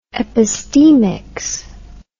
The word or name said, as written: epistemics